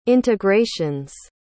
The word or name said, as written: integrations